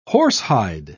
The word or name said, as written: horsehide